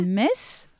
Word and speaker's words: Metz: On doit dire aussi Metz.